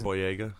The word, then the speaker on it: Boyega: Boyega? How do you say it? – Boyega.